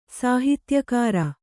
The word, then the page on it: ಸಾಹಿತ್ಯಕಾರ: Kannada